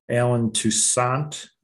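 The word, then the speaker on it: Allen Toussaint: Alan Toussaint.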